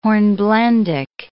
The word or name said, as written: hornblendic